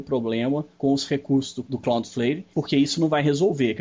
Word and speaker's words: Cloudflare: Porque a palavra é Cloud, que é de nuvem, e Flare, F-L-A-R-E. Então, Flare. Cloudflare. Deve ser sim a pronúncia correta.